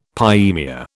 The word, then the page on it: pyaemia: British English